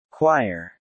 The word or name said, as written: Choir